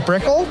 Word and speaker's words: Brickell: You have two offices in the Miami area, in Brickell. Did I say that right? – Correct.